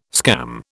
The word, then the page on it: scam: British English